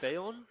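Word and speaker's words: Beorn: It is Bayorn. It was Bjorn.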